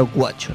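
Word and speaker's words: Güacho: Guacho se escribe con diéresis en la U. – Sí. – Es porque se pronuncia Guacho, ¿no? – Sí.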